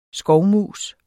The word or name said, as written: skovmus